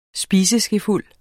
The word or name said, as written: spiseskefuld